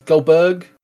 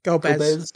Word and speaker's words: Golbez: We'll face Golbez. Is that how you say it? Golbez? Goldberg? – Yeah. Golbez.